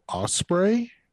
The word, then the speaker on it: Ospreay: Uh, number seven on the top 10 list of 2020 wrestlers, Will Ospreay. Am yeah. I even saying that right? Yeah, yeah you are. Yeah, you nailed it.